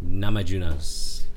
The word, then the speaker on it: Namajunas: Namajunas.